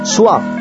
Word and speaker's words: Swa: Óigase bien, Om, que es O, y luego y después es la M de mamá, y por último la letra Swa, o la palabra Swa, que significa sol o Zeus, que es la letra S, la letra U y la letra A, Swa.